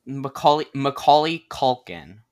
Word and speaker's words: Macaulay Culkin: Macaulay Culkin. That's his name. Okay, you just really have to get those syllables out and really enunciate.